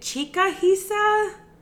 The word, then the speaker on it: Chikahisa: And then is it Chikahisa? – Yes, that's perfect.